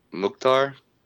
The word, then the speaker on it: Mukhtar: And then in the Nashville game, man, Honey Mukhtar, dude, hat trick in the 10th, 13th, and 16th minute. Talk about efficiency. – How do you say that last name again? – Mukhtar.